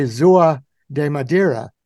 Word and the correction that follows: São João da Madeira: How am I very close? Yeah, almost. It says São João da Madeira.